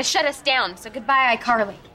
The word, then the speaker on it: iCarly: Either that, or we'll have to shut down Ickerly. – It's pronounced iCarly. – Ah, sorry, iCarly. That's my hat.